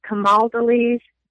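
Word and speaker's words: Camaldolese: Let's see, then a little bit more, and I hope I pronounced this right, a Camal Deliz Benedictine Oblate. Go ahead. – Fix it. Yeah, you got it almost. Camal Deliz Benedictine.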